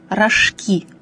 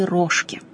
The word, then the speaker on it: рожки: А самое главное, что, собственно, и вызывает наибольшее затруднение, произносится с ударением на последнем слоге – рожки, а не рожки.